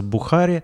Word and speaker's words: бухари: То есть там просто, ну, в грузинском языке нет ударений, поэтому нараспев произносится «бухари», а в русском просто поставь ударение на последний слог, и будет очень смешно.